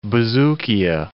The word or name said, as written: bouzoukia